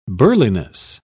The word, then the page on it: burliness: British English